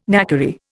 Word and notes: knackery: Indian

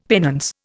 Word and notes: penance: Indian

